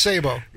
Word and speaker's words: Sabo: Still pronounced Sabo. – And, oh, is it? It's still, okay, Sabo. – The T is silent.